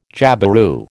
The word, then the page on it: jabiru: American English